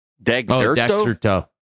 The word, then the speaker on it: Dexerto: Yeah, there's this list on what, how do you say it? Dexerto? – Dexerto.